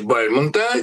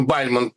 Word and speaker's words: Бальмонт: Это, как мы понимаем, Эдгар По в переводе Бальмонта, те самые Бальмонт, правильное ударение, а не Бальмонт, те самые колокола.